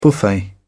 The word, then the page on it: buffet: British English